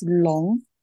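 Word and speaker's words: Long (龙): Dragon in Mandarin, pronounced as Long, are seen as powerful, ambitious, and charismatic.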